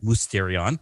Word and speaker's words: Mysterion: He translates the Greek word Mysterion, or I'm not a Greek scholar, but I've been told the Y is better pronounced as like a U, so it's like, right. Mysterion.